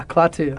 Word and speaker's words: Klaatu: We're taking off to Klaatu. Did I say that right? – Yeah, Klaatu.